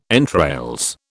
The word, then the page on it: entrails: British English